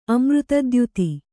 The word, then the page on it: ಅಮೃತದ್ಯುತಿ: Kannada